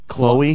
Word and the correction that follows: Clow: Clow? – Spell it. – C-L-O-W. – Oh, Clow.